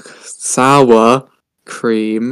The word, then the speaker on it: sour cream: Okay, Sour Queen and Onion, barbecue. – Yeah. Did you say Sour Queen? – Okay, I can't speak. Sour Cream.